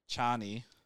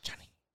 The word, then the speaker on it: Chani: And then her character, whose name I can't pronounce, Chani. – Oh, Mary Jane. – Chani.